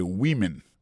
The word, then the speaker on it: women: Mais tandis que c'est le mot femme nan, au pluriel, qu'on n'a pas prononcé woman, encore c'est women, many women.